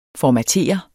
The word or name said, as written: formatere